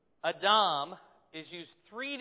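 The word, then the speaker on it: Adam (אדם): Yeah, it's Adam, which sounds like Adam, okay?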